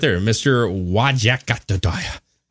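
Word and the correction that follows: Wajackoyah: I'm going to say his name is Wahak Oya.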